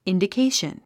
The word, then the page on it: indication: ìndikéiʃən; インディケィション